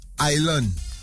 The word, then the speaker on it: island: Island. As a foreigner, where the S is, what that, why that there? – Oh, okay. – So we would say Island because we're gonna pronounce it with spelt Island.